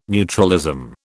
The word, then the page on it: neutralism: British English